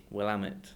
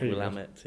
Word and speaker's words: Willamette: I'm probably saying that wrong. Willamette. – Yes. – I was told Willamette. Yeah, okay. Rhymes with something else, I won't say.